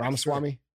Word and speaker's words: Ramaswamy: For me, but Vivek Ramaswamy, is that how you say his name? – Yeah, Ramaswamy.